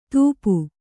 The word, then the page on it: ಧಾಪು: dhāpu